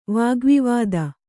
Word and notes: ವಾಗ್ವಿವಾದ: Kannada

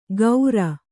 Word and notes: ಗೌರ: gaura

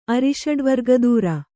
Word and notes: ಅರಿಷಡ್ವರ್ಗದೂರ: kannada

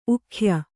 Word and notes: ಊಗಾಯ: Kannada

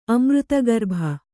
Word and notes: ಅಮೃತಗರ್ಭ: kannada

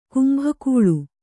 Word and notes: ಕೊಂಬುಗೊಳ್: kannada

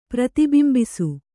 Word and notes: ಪ್ರತಿಬಿಂಬಿಸು: Kannada

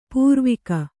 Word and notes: ಪಾರ್ವಿಕೆ: pārvike

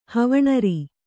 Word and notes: ಹವಣರಿ: havaṇari; Kannada

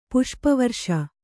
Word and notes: ಪುಷ್ಪವರ್ಷ: Kannada

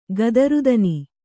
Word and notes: ಗದರುದನಿ: gadaru dani

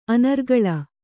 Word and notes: ಅನರ್ಗಳ: anargaḷa

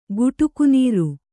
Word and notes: ಗುಟುಕುನೀರು: guṭuku nīru

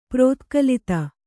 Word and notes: ಪ್ರೋತ್ಕಲಿತ: prōtkalita

